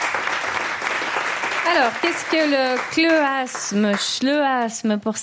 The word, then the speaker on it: chleuasme: Parce que je pense que ça se prononce chleuasme, en effet.